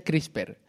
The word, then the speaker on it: CRISPR: que es veu que es pronuncia CRISPR.